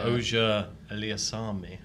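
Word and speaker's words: Auger-Aliassime: Oja Eliasami. – It's a good pronunciation. – Yeah, I heard it on the TV. I was like, oh, we've been saying that wrong.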